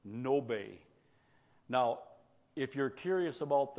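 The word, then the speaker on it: Ngäbe: Handy little tool to learn some more about these people called Nobe, N-G-A-B-E. That doesn't sound like Nobe. The G is silent. The A has two dots over it.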